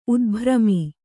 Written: ಉದ್ಭ್ರಮಿ

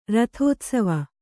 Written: ರಥೋತ್ಸವ

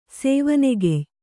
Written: ಸೇವನೆಗೆಯ್

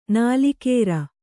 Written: ನಾಲಿಕೇರ

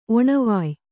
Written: ಒಣವಾಯ್

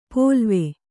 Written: ಪೋಲ್ವೆ

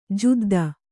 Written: ಜಿದ್ದು

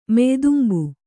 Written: ಮೆಯ್ದುಂಬು

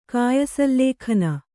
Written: ಕಾಯಸಲ್ಲೇಖನ

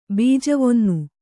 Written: ಬೀಜವೊನ್ನು

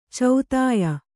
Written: ಚೋದ್ಯ

